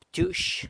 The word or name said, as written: btyux